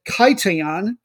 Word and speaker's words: Kition: Their fuller name is Athletic Union, I'm going to pronounce it Kaition, K-I-T-I-O-N, of Larnaca.